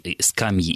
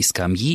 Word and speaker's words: скамья: Что во всех формах возможно оба ударения «скамьи» и «скамьи», и «о скамьях», и «о скамьях».